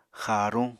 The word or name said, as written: xaaruñ